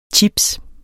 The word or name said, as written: chips